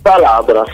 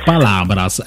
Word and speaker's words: Palabras: vediamo se lo pronuncio nel modo giusto, Palabras, giusto? – Palabras. – Palabras, ecco, caduto subito con la pronuncia, quindi.